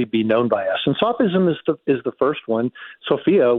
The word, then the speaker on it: sophism: Is it sophism? Sophism? – Sophism, yes. So yes.